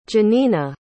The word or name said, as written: jeanina